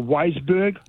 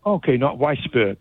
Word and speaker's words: Weisberg: The other thing is, I was interested that you said when there's two S's, it's soft pronunciation, and when it's one S, it's like a Z. So your W-E-I-S, your Weisberg. – Yes. – Not Weisberg. Okay.